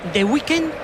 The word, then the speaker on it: The Weeknd: Yo creo que es The Weeknd.